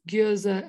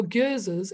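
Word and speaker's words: Gueuze: Your Gyozes, and I actually think I'm saying it wrong. I think it's Gyoz, but yes.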